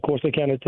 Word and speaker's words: Corsicana: Is it Corsicana? – Yeah, Corsicana, Texas.